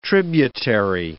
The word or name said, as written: tributary